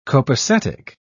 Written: copacetic

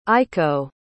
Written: aiko

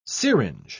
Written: syringe

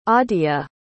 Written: Aadhya

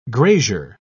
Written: grazier

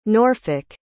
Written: Norfolk

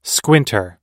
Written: squinter

